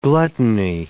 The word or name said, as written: gluttony